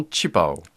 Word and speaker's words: 旗袍: on dit directement qi pao.